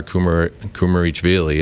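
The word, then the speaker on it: Kumaritashvili: It was Nodar Kumarichvili is how you pronounce it.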